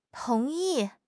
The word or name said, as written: tong2 yi4